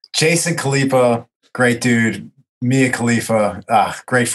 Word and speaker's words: Khalipa: Is it Khalipa? Jason Khalipa. – Jason Khalipa. Yeah.